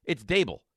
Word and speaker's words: Daboll: It's not Daboll. It's Daboll. It's one of the easiest names in the world to pronounce. Say it with me. Daboll. – Daboll. Like Daboll. Table? – Yeah, it's easy.